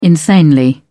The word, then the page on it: insanely: British English